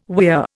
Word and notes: weir: Indian

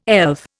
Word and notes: elf: Indian

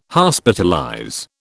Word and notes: Hospitalize: British English

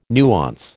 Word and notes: nuance: American English

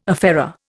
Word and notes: affair: Indian

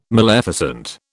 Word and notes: maleficent: British English

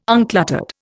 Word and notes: uncluttered: Indian

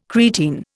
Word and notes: creatine: Indian

